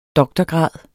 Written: doktorgrad

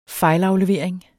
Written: FEJLAFLEVERING

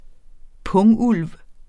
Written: pungulv

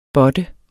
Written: båtte

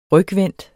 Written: rygvendt

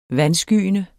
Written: vandskyende

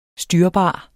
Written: styrbar

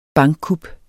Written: bankkup

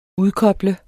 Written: udkoble

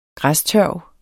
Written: græstørv

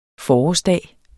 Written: forårsdag

